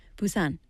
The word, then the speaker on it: Busan: We don't say 부산. Busan, short A. In Busan, we say Busan.